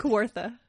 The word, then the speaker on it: Kawartha: Am I saying that right? – Kawartha. – Kawartha Lakes region.